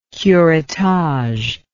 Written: curettage

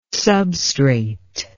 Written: substrate

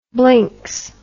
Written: blinks

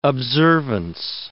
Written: observance

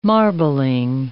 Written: marbling

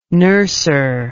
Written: nurser

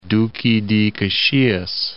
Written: Duque de Caxias

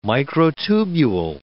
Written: microtubule

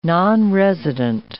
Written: nonresident

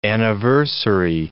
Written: anniversary

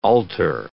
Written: alter

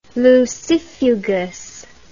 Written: lucifugous

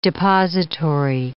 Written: depository

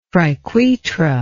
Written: triquetra

